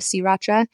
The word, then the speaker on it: Sriracha: Fun fact, we're actually saying it wrong. It is called Sriracha. I learned that. It was named after the coastal Thai town of Sriracha.